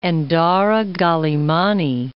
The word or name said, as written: Endara (Galimany)